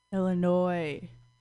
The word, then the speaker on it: Illinois: Illinois, no S. Oh, look at that. I guess I can't even pronounce Illinois right.